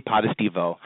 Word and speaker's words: Potestivo: I say it, Vinnie Potestivo.